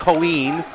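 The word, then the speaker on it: Coín: That's pronounced Coin.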